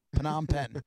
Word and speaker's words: Phnom Penh: Phnom Penh.